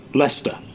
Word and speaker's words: Leicester: And remember, I've just said that the town is pronounced Leicester. – Uh-huh. – And it's spelled L-E-I-C-E-S-T-E-R. Excuse me, but pronounced Leicester.